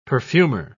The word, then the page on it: perfumer: British English